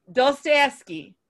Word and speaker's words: Dostoevsky: Somebody pronounce it. – Fyodor Dostoevsky. – Dostoevsky. – You nailed it. Nailed it.